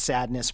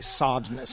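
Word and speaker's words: Sadeness: Yeah, and a lot of people pronounced it Sadness, but I suppose if you're being very specific, you should say Sodness.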